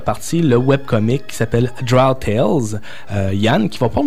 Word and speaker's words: Drow: C'est sûr, certain qu'au Québec, on a tendance à dire Drow ou Drow. La bonne prononciation, c'est Drow, si je me trompe exact.